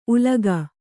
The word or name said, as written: ಉಲಿಗ